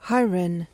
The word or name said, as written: hiren